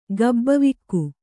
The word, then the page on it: ಗಬ್ಬವಿಕ್ಕು: Kannada